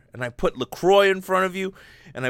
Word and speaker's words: LaCroix: They called it LaCroix because it rhymes with enjoy, because they're trying to push it like people enjoy LaCroix.